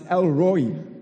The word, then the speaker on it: El Roi: Okay, so here's the first one, which is apparently pronounced El-Roi. El-Roi.